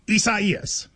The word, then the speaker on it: Isaias: A lot of people, you know, prayers to everybody involved, but it is Isaias.